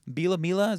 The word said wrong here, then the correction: Bielema: Is that how you say it? – Bielema? – Whatever.